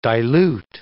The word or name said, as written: dilute